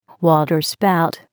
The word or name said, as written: waterspout